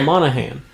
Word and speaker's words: Monaghan: Yeah, Monaghan. – So for those of you who watch Gotham... – You gotta get your, like, kind of Gaelic, Scottish, Irish pronunciation.